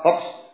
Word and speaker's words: hoax: Di sini menantina azabumullah tegas menunjukkan kepada kita bahwasanya Islam sangat menentang yang namanya semua berita-berita hoak. Gimana bacanya? Hoak apa? Hoak apa? Hoax. Hoax, ya Allah.